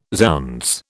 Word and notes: zounds: British English

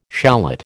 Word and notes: shallot: American English

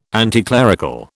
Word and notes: anticlerical: British English

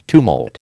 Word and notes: tumult: American English

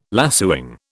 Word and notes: lacewing: British English

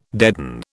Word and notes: deaden: British English

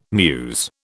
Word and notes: mews: British English

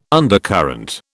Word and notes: undercurrent: British English

